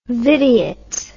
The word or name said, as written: vidiot